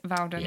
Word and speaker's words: Vowden: It's at Charlotte Vowden. So my surname is spelt V for Victor, O-W-D-E-N for November, because it can sound like Bowden, Wowden, so it's Vowden. – Yeah. – So at Charlotte Vowden.